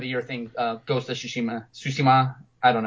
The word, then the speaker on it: Tsushima: Tsushima. – Tsushima, thank you.